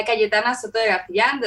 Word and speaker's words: Cayetana Soto de Garcillan: So my name is uh, Cayetana Soto de Garcillan.